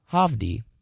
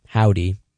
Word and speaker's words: Hovvdy: That last song you heard was Problem by Hovdy, or Howdy, depending on how you want to pronounce it. H-O-V-V-D-Y, by the way.